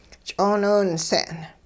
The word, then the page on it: ch'ooniwinse'n: hupa